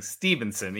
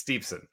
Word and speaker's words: Steveson: It's spelled Steveson, so it is, but naturally, I keep saying Stevenson, even though it's Steveson.